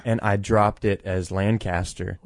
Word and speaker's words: Lancaster: And enjoyed some coffee down in Lancaster. – Yeah. – So you said it right. – I did, didn't I? – Lancaster.